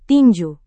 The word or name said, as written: tinju